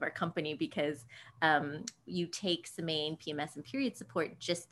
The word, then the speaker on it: Semaine: But that's really why we started Semaine, and you pronounced it exactly as we pronounce it, at least, which is not the French way of pronouncing it, but we pronounce it the American way. So Semaine is exactly how we say it, and it does mean the week in French.